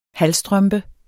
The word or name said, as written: halvstrømpe